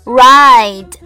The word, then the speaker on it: ride: 这里的 I 读的时候，嘴巴要尽量张大，发音要圆润。 I I ride give somebody a ride 就是顺便搭载某人，让某人搭便车的意思。